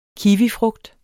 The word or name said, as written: KIWIFRUGT